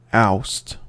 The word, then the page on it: oust: American English